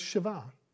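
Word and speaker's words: Shiva (שבע): Now, in Hebrew, the number seven is pronounced Shiva.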